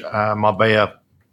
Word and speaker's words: Marbella: It's double L, but obviously in Spanish that's pronounced with a yeah sound, so it's Marbella.